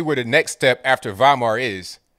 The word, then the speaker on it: Weimar: The only thing people got to do is Google the Weimar Republic. It's pronounced like a V, but it's spelt with a W. That's the way Germany does it.